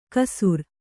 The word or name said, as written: ಕಸುರ್